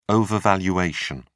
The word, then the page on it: overvaluation: British English